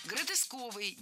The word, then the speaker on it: гротесковый: Гротесковый, неверно. Правильный вариант – гротесковый. Кстати, если мы хотим образовать от существительного «гротеск» прилагательное, то предпочтительным вариантом будет слово «гротескный» с однозначным ударением на втором слоге. Вариант «гротесковый» тоже допустим, но ударение на втором слоге сохраняется.